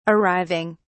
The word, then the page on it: arriving: British English